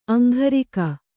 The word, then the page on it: ಅಂಘರಿಕ: aŋgharika